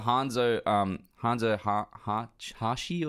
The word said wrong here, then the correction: Hanzo Hasashi: Hanzo Hasashi.